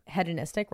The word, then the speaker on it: hedonistic: Finally, we have the You're saying it right. It sounds right. – Hedonistic, right? I think it sounds right too.